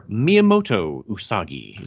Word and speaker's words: Miyamoto Usagi: With this being episode 90, we have to do a character spotlight, and one character we've never done a spotlight on is Usagi, or Miyamoto Usagi. Is that how you say it? – Yes.